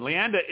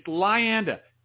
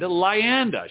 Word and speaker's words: Lyanda: Lyanda is an award-winning author, naturalist, and ecophilosopher and speaker who's writing, wait a minute, I mispronounced her name. It's Lyanda. God, nagget. Lyanda. Lyanda, people. Not Lyanda. Lyanda.